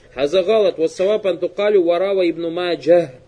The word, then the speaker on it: Маджа: Что касается Ибн Маджа, Маджа это имя человека. То есть и обязательно надо произносить эту га. Хоть ты останавливаешься или же соединяешь другим словом. Фаля юкалю раваху ибну маджата. Это неправильно, то есть там арбуты там нет.